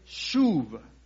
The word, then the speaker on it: shuv: Mire, por favor, la palabra confortar es una palabra hebrea que es shuv, shuv, así se pronuncia, shuv.